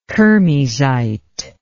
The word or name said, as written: kermesite